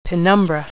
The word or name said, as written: penumbra